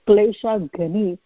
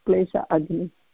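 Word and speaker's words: kleshaghni: So I was just correcting the pronunciation that it has to be said as klesha ghani instead of klesha agni.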